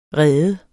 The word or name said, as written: rede